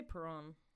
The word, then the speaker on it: Perron: I'm going to say Perrin family. It's P-R-R-O-N, but it's like Perrin or Perrone or... – I'd say Perron.